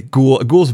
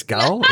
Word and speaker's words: Gaul: Ghoul's better, but I think it's Gaul.